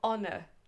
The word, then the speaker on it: honor: honor. honneur donc avec un H initial qui ne se prononce pas. Honor.